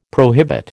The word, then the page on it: prohibit: American English